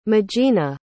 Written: magena

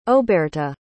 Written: auberta

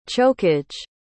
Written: chokage

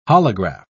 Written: holograph

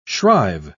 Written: shrive

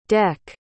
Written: dec

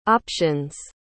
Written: options

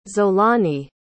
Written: xolani

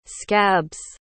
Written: scabs

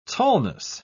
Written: tallness

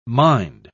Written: mind

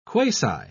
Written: quasi

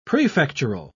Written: prefectural

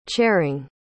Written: chairing

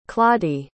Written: claudie

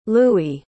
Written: lewi

